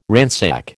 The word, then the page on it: ransack: American English